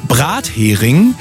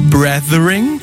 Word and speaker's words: Brathering: Brathering essen Engländer, die das Wort mit TH aussprechen, Brethering.